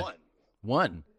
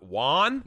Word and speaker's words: WON: Hey, how do you pronounce WON? – WON? – Yeah. – One. – Oh yeah. Not Juan? – Not Juan? – No, not Juan.